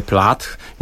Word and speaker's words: Plath: No, dzisiaj przyniosłem wspomnianą Sylwię Plath. Nigdy, bo to trzeba to TH dobrze wymawiać, to był mój wielki kompleks, jak się uczyłem angielskiego, Plath? Bo mówiłem Plath, Sylwia Plath.